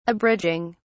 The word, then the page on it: abridging: British English